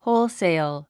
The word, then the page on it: wholesale: hóulsèil; ホウルセイル